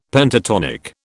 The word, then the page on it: Pentatonic: British English